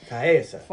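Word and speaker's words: Taissa: Tessa? I mean, it's T-A-I-S-S-A. – Taysa? – Taysa? – Taysa.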